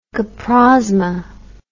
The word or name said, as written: coprosma